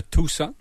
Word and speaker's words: Tucson: Tucson, il est dans les le Tucson, parce qu'on prononce Tucson comme la ville de Tucson.